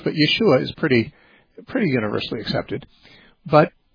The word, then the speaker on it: Yeshua: So, yeah, Jesus would have been, his name would have either been pronounced Yeshua or Yahshua. Some people think Yahoshua.